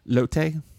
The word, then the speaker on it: Lotte: Lotte. – Lotte. Lot. Like that.